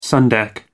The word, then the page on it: sun deck: American English